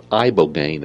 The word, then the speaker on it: Ibogaine: He's had 20 years' experience in studying something called Ibogaine.